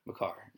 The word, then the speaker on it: Makar: Kale Maker, yeah. – Makar.